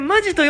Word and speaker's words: 本気: うん、本気と書いて、マジと読むときだけ、そのままで喋ってってね。